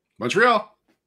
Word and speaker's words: Montreal: Montreal. – All right. – And you even pronounced it right. I hate when it's like a Montreal. It's like, that's Montreal.